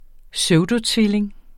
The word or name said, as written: pseudotvilling